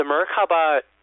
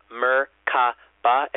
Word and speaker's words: Merkaba: The Merkaba, it's a three-syllable word, Merkaba, and it really represents the vehicle that which spirit moves to move into the higher dimensions.